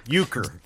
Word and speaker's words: Euchre: It's spelled Y-O-O-K-E-R. Euchre.